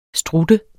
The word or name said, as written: strutte